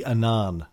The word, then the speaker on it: Annan: By the way, I think it's pronounced Kofi Annan, not Kofi Annan.